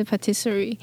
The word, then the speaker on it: Patisserie: 好，那我们今天邀请到的这个来宾是来自这个 NK Patisserie 甜点工作室。 – 耶，让我欢迎他。 – 呃，嗨，大家好。 – 嗯，我刚我刚念的那个发音是对的吗？ – 啊、呃，其实是它是法文，啊，发音是 Patisserie。